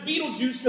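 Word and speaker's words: Betelgeuse: It's got maybe a couple of pronunciations. You could either pronounce it beetle Beetlejuice because that's what the scientists have named it.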